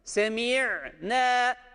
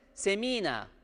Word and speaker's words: semi'ne: Semi'ne, semi'ne. Bu doğru okuyuştur. Yanlışı nedir? Semina. Bakın bu ya harfi burada yoktur met harfi.